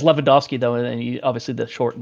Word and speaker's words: Lewandowski: people who say Lewandowski. – I mean, he is Lewandowski, so... – Wait, people say Dowski? – No, I say Lewandowski. – Lewandowski, yeah. Like the W's an F, yeah.